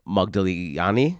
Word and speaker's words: Modigliani: I did kind of skip my way into lingerie to see the Modigliani show.